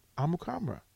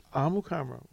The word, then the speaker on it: Amukamara: Did you learn did you learn Prince's last, how to pronounce his last name? – Amukamara. Never would have guessed it. – Amukamara. We've been doing this wrong for years.